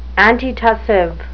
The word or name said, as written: antitussive